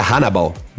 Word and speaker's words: Hannibal: Agora, agora, eu achei engraçado na transmissão o camisa 10, que o nosso tunisiano Rasta, parece que pronuncia Hannibal, que escreve Aníbal.